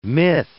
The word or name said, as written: myth